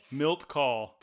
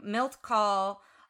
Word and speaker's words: Milt Kahl: Milt Call. – Thank you. Milt Call.